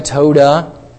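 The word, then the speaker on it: todah: Because the word is simply, you might pronounce it this way, toda. All right, if you were to pronounce the actual word that's behind it.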